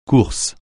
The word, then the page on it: course: French